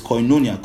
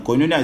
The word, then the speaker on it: koinonia: That word communion, like I said, is koinonia. Koinonia is spelled K-O-I-N-O-N-A-I. Sorry, K-O-I-N-O-N-I-A.